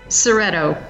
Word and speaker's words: Szereto: I was actually going to start the podcast asking you, how do you pronounce your last name, Mitzi? – Szereto. – Szereto. – Yeah, the Z is silent.